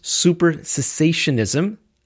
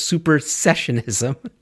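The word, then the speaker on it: supersessionism: the technical term for it is super cessationism, and I think I said it wrong again, it's super cessationism, more popularly known as replacement theology.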